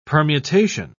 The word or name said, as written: permutation